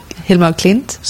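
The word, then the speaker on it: Hilma af Klint: Hilma af Klimt.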